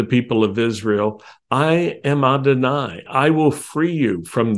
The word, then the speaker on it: Adonai: I appeared to Avraham, Yitzchak, and Yaakov as El Shaddai, although I did not make myself known to them by my name, Yod-Heh-Vav-Heh, which we pronounce Adonai.